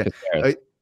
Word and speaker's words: Caceres: Caceres.